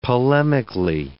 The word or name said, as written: polemically